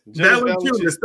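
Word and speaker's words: Valančiūnas: Valanciunas.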